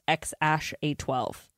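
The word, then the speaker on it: X Æ A-12: And they say it's pronounced X-A12.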